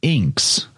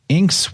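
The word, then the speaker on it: Iynx: This is called an Inx. I think that's how you say it. It's I-Y-N-X.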